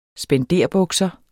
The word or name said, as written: spenderbukser